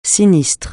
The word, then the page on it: sinistre: francais